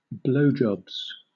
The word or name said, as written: blowjobs